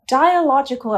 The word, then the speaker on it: dialogical: You've been putting forward the dialogical approach. Am I saying that right? – Yeah, absolutely.